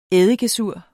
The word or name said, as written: eddikesur